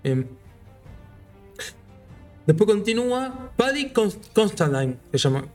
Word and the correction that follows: Considine: Considine. ¿Cómo se pronuncia? Paddy. Creo que es eh, Considine. – Considine. Paddy Considine.